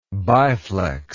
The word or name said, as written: biflex